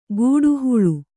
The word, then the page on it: ಕಾಡ್ಹಳ್ಳಿ: kaḍ-haḷḷi